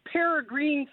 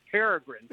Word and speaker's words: peregrine: And she pronounced it like that. So we're little kids look up there saying, how can she see two green birds all the way up there? And we did not laugh because she'd walk this one. But because she mispronounced it. It was years before I realized it was peregrine.